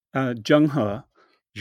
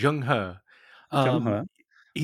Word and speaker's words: Zheng He: Am I saying his name right? – Zheng He. – Zheng He.